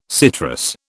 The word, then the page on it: citrus: British English